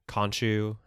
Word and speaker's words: Khonshu: I mean, we did see a lot of the little statues at the end when they were looking for Kanoshu or Khonshu, how they say it in the show.